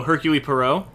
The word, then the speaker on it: Hercule Poirot: I think it's Urkelly. I think, or is that how they say it? Which guy are you talking about? Uh, Kenneth Branagh's character. I'm sure everyone like. – Oh, for, oh, Hercule Poirot.